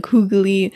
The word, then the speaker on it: hygge: which is H-Y-G-G-E or H, no, H-Y-G-G-E. Yeah. Which is pronounced hygge, or they say like hoogly.